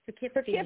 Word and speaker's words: Poughkeepsie: It looks like it may be Poughkeepsie, or is that Poughkeepsie? – Poughkeepsie, New York. – Poughkeepsie, New York. Okay. I'm like, yeah. Poughkeepsie.